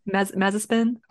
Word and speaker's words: Mazepin: No, because Mazepan is the reason why they don't want that. – He might spin. Isn't that how you pronounce it? – Mazepin.